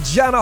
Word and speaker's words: Jana: Jana Hocking. And yes, I've got your name right.